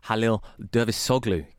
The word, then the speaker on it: Halil Dervişoğlu: Halil Dervişoğlu came in from Sparta Rotterdam.